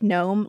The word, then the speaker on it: Nome: So we learn that gnome is the end point and it's N-O-M-E, but it's pronounced gnome like a garden gnome, which is fun.